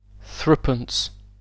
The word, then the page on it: threepence: British English